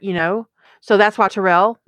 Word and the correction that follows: Tauriel: that I said that wrong again. Tauriel.